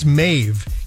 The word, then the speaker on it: Maeve: I don't know I'm not sure how to pronounce it, really. I think it's Maeve. M-A-E-V-E. M-A-E-V-E.